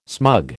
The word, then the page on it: smug: American English